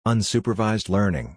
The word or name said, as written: Unsupervised Learning